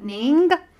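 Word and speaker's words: Ng: Ning.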